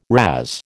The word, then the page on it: razz: American English